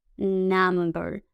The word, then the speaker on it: number: Se escribe con U, N-U-M. Pero las U's en ocasiones en inglés suenan como si fueran una A. Entonces no es number, sino que es number, number, ¿ok?